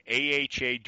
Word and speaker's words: AHA: We are on Spotify, we're on Google Podcasts, and we are on AHA Directory for your car 24 7 with motivation muscle and it's pronounced aha aha